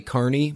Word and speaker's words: Kearney: The pronunciation of Kearney, Missouri is actually Kearney, Missouri.